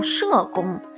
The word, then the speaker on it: 叶公: 那我们今天来讲一讲叶公好龙这个故事。说啊，在这个春秋时期的楚国，有一个人叫叶公。那么在古代呀，这个叶子的叶又读作社，也可以叫社公。